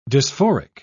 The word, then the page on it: dysphoric: British English